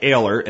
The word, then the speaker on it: Oehler: Ehler, and it's spelled O-E-H-L-E-R. His last name Ehler, but it's spelled with an O. O-E-H-L-E-R.